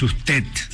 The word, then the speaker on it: usted: Y Antonio decía, hay que pronunciar la D como T, para que pueda ser, para que tenga fuerza en el final. Es usted. ¿Me explico? – Sí. – No es usted, porque D no tiene fuerza en el final.